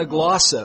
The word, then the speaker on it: glossa: So, he who speaks in a glossa, it was a known language, G-L-O-S-S-A is how we pronounce it, does not speak to men but to God.